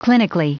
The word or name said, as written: clinically